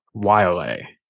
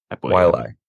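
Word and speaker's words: Waialae: We got, how do you pronounce it? The Wiley or Wiley? I always, every year we come up to this. – Wiley. – Wiley. The Wiley Country Club.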